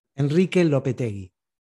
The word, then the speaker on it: Lopetegui: Enrique Lopetegui.